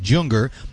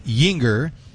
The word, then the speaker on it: Juenger: J-U-E-N-G-E-R. Looks like Junger, but it's pronounced Jeunger.